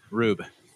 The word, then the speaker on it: Rube: Rube. Rhymes with noob.